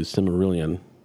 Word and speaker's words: Silmarillion: The showrunners do not have the rights to the Silmarillion.